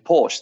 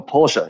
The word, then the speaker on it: Porsche: For example, you know, my girlfriend did a campaign with Porsche, the German car company, or Porsche, if you're pronouncing it properly. My Austrian mother would be very saddened if she heard me pronouncing Porsche incorrectly.